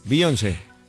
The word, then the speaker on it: Beyoncé: Beyoncé. – ¿A Beyoncé? – Beyoncé. – Sí, Beyoncé. – Se pronuncia. – Sí, sí, sí, Beyoncé.